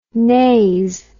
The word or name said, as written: nas